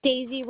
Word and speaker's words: Deisy: Daisy Rodriguez, but my first name is spelled D-E-I-S-Y. – D-E-I-S-Y? – Yes.